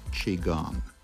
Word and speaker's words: Qigong: The practice is called Qigong, spelled Qigong, pronounced Qigong.